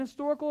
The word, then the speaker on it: historical: The resurrection, it just was an historical event. And you have to use the word "and" there because the H is silent.